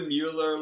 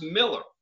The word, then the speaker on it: Mueller: Stop being some hipster and be like, you go to the Mueller, like, you know, the Locadona, like the, and I'm like, you mean Miller? They're like, Miller? I'm like, yeah, it's pronounced Miller.